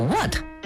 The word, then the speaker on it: what: 英文里边这个 what 什么，里边这个 H 啊也是不发音的。